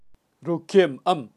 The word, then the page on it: rukem am: Kaqchikel